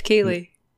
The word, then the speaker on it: Keighley: Jeff N- Niley? How do you say his fucking name? – Jeff Keighley.